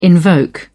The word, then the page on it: invoke: British English